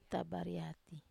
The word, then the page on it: tabariati: Wamesa